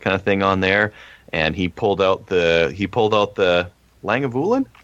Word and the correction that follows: Lagavulin: Lagavulin. – Yeah. Lagavulin.